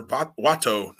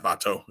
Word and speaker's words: Wato: El Desperado and Yoshinobu Kanemaru, um, the champions defend against Master Wato, not Vato. It's, it's, not Hispanic, Jim. It's Japanese.